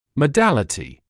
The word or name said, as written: modality